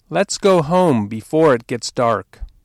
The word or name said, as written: go home